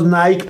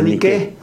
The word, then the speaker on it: Nike: O sea, que nosotros le decimos Nike por la marca. – Sí. – Pero se pronuncia Nike.